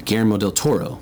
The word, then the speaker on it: Guillermo del Toro: Yeah, and I said that right, Guillermo del Toro. – Yeah.